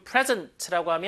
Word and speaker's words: present: 저걸 앞에다가 항상 좀 present라고 하면 선물이죠. present. 그건 완전히 다른 단어고. 스펠링은 같지만. present. 한번 읽어볼게요. present. 한번 더. present. 이렇게 지음 주면 제공하다, 선사하다 이런 뜻이죠.